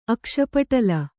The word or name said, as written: ಅಕ್ಷಿಪಟಲ